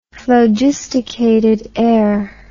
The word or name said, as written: phlogisticated air